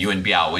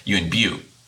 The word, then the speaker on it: Yuen Biao: But obviously in Cantonese, so when you have people talking about him in Cantonese, you know, for example, our friend Sifu Alex at the Kung Fu Genius podcast, they're going to use the Cantonese pronunciation of Yuen Biu.